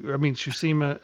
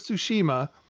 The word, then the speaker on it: Tsushima: I'm going to play Ghost of Jiu-Jitsu. Tsushima. I fuck it up every time. God damn it. I made the joke two times, and now every time I can't say it right. Ghost of Tsushima.